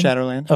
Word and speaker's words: Shadowland: Yeah, Shadowland.